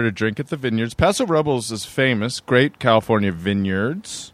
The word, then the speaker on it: Paso Robles: I grew up in Paso Robles, California.